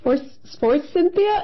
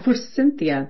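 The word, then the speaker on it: Forsythia: I don't even know how to pronounce it either. For Cynthia? Is that it? – For Cynthia. That's right.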